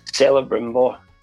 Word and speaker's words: Celebrimbor: How do you pronounce his name again? – Celebrimbor. – There you go.